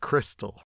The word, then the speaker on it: KRXSTXL: So it's KRX, quicks, quicks, and then STXL. So if you put it together, it's quicks. Confused? The name is pronounced Crystal.